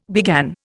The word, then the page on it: began: Indian